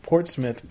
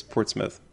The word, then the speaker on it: Portsmouth: This first one was we pronounced Portsmouth, Portsmouth. – I might have done that by mistake. I know that Portsmouth, New Hampshire is Portsmouth, but...